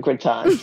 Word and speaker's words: gratin: Do you pronounce it gratin? Do you lean into the French pronunciation? – Of course I do, gratin.